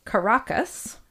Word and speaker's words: Caracas: Well, in the capital, Caracas, am I saying that right? – Yeah sounds sure.